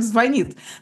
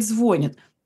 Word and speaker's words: звонит: Мы знаем, что говорить правильно звонит, но огромное количество людей говорит «звонит».